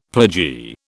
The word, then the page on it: pledgee: British English